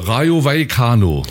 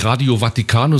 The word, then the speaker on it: Rayo Vallecano: Rayo Vallecano. Nicht Radio Vaticano, sondern Rayo Vallecano. So ungefähr.